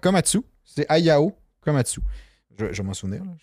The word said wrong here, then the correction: Ayao Komatsu: Ayao. A-Y-A-O Komatsu. Mais en tout cas, on va dire Komatsu.